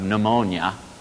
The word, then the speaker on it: pneumonia: And even in English now, you know, if we don't pronounce the P and don't pronounce the U as a V, as they do in modern Greek, you know, if you have pneumonia, it's a disease of the breathing organism of the lungs.